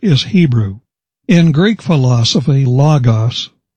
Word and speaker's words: logos: The Greek word is pronounced logos.